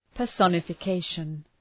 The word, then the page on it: personification: pər,sɒnəfə’keıʃən